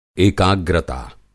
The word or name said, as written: एकाग्रता